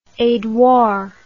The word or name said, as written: edouard